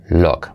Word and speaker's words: look: Fijaos que eso, look es aspecto, pero en inglés no se dice look, sino look, ¿vale? U como so.